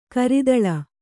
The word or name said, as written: ಕರತಾಲ